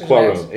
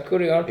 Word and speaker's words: Cuarón: Alfonso Cuarón. Cuarón. There's a. It's just it's Cuarón. Yeah. Okay. It's uh, Cuarón.